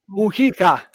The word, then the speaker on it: Mujica: Mujica.